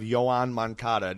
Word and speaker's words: Yoan Moncada: Well, opening day we're going to have Yoan Moncada.